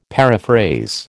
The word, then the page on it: paraphrase: American English